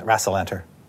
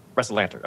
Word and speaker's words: Rassalanter: Uh, how is it pronounced? It was Rassalanter. – Rassilanter. Okay. Yeah. yeah. I had that right.